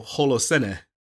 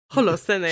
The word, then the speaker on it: Holocene: See, my brain my brain wants to go Holocene. – Holocene.